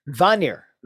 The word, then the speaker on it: Vanir: Vanir. Vanir is V-A-N-I-R.